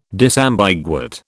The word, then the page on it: disambiguate: British English